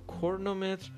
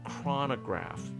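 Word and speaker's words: chronograph: کرنومتر که البته تلفظ کاملا غلطی هست کرنو نیست این کرونو به انگلیسی این میگن کرونوگراف یا کرونوگراف کرونو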